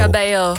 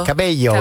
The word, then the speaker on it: Cabello: Camila Cabello en Havana. Of hoe zeg je dat nou? Camila Cabello. – Cabello. – Cabello.